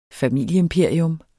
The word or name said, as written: familieimperium